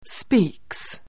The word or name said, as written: speaks